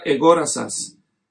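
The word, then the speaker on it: egorazas (ἠγόρασας): Esta se pronuncia egorazas.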